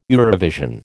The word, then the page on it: Eurovision: American English